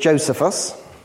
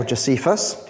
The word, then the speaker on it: Josephus: There's a Jewish historian called Josephus, okay, or Josephus, depending on how you want to pronounce it.